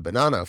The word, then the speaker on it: Bonanno: The Bonanno family. Yes, that's how it's said.